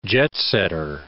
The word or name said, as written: jet-setter